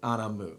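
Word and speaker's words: A'bunadh: Cask Anamu. That's absolutely correct.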